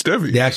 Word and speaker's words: Devi: I think it's Devi. – They actually pronounce it as Davy in the audiobook, but do they? – We can call it whatever we want.